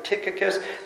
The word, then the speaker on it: Tychicus: Some people pronounce it Tychicus. The Y in the Greek actually is an O sound, like you looked or you choose, right?